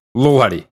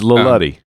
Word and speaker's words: Lil Huddy: Yeah, you got too much enunciation in your pronunciation. Yes. – All right. And in fact, in this version, there's not even a space. So it's Lil Huddy.